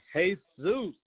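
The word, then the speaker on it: Jesús: Their names, a lot of them, they took on this name, Zeus, and they spell it J-E-S-U-S. It's pronounced Jesus. That's right. – Jesus. – They say, hey, Zeus.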